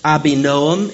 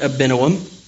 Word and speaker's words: Abinoam: Reading on in verse 6, she sent and summoned Barak, the son of Abinoam, that is pronounced Abinoam in Hebrew, but we'll call it Abinoam, from Kadesh Naphtali.